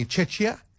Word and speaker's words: Czechia: They play Czechia. That's how you say it.